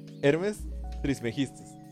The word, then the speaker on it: Hermes Trismegistus: Por eso este, ya para entrar de lleno al tema del hermeticismo como, como un sistema de creencias, es que eh, este Hermes Trigemistus, así se dice. No, no lo dije bien, pero, lo, lo, voy a decir bien. Hermes Trismegistus. Así lo había dicho bien, creo.